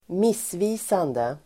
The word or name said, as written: missvisande